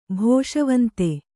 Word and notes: ಭೋಷವಂತೆ: Kannada

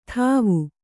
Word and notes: ಥಾವು: kannada

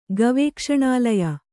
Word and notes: ಗವೇಕ್ಷಣಾಲಯ: Kannada

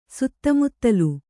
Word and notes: ಸುತ್ತುಮುತ್ತಲು: suttu muttalu

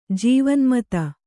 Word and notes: ಜೀವನ್ಮತ: Kannada; jīvanmřta

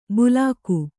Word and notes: ಬುಲಾಕು: Kannada

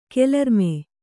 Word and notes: ಕೆಲರ್ಮೆ: kelarme

